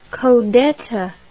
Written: codetta

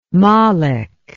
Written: malik

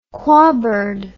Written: quabird

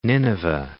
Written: Nineveh

